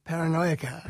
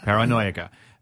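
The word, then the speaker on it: Paranoiaca: Now, is this a personal journey, this encyclopedia, and how do I pronounce it? Paranoica? – Paranoica. – Paranoica.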